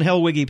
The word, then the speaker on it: Helwege: As if Helwig, W-I-G. No, and the E is at the end, but the E is silent, keeps its peace. Pastor John Helwege, pastor of Emmaus Lutheran Church in St. Louis, thanks for coming by.